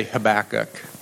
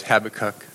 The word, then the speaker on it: Habakkuk: He's one of the minor prophets, and I say Habakkuk. Debbie says Habakkuk. So it all depends on, you know, which syllable you put the emphasis on.